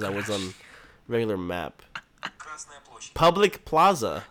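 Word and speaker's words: Красная площадь: In Russian, red square is Krasnaya Площадь. – Krasnaya Площадь. – Krasnaya Площадь.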